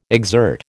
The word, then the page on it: exert: American English